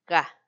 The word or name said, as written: ga